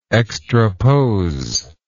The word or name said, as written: extrapose